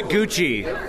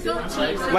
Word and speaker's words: Gucci: Michelle, how do you say this name? I know it's not Gucci. – More like Gucci. – Like Gucci. Okay.